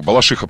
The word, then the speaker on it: Балашиха: Балашиха, правильно ударение.